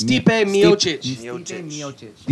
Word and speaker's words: Stipe Miocic: How do you say it? – Stipe Miocic.